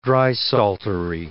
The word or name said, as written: drysaltery